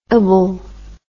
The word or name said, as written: ible